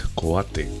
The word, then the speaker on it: Coate: Bob Coat, Coate. Pronunciado exactamente como se escribe.